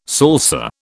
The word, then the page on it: salsa: British English